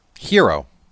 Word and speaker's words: gyros: The gyro. – I guess you could say gyro. My cousins in California call them heroes. – But they're spelled like gyro? – Yeah. They pronounce the G as a Y.